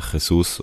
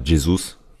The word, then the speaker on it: Jesus: momentan haben, klar, mit Jesus oder Jesus, ist ist ja Brasilianer und wird dann ja wahrscheinlich Portugiesisch ausgesprochen, Gabriel Jesus.